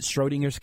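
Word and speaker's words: Schrödinger: Maybe I can call it, like, isn't it Schrodinger's cat?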